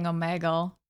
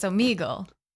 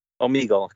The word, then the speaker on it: Omegle: Yeah, and I made you say it because I always say it wrong. I'm always saying Omegle, but it's Omegle. – Omegle, correct.